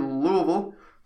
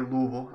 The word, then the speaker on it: Louisville: Louisville. Pronounced that way, Louisville. I'm very clear about that.